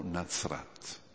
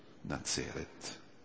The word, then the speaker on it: Nazaret: Netzer, in ebraico, Netzer, Nazaret, si dice Nazaret in ebraico, o Nazrat, che è la stessa cosa, Nazrat o con una pronuncia più dolce, Nazaret, si può dire Nazrat o Nazaret, Netzer, le consonanti sono quelle, un virgulto, Netzer.